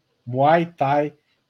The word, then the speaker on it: Muay Thai: Muito bem, lutadora de Maitá. – Desculpa, falei errado. Muay Thai, Jade Beatriz.